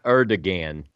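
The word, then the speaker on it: Erdoğan: Not Erdogan. Erdogan. The G is pronounced as a W.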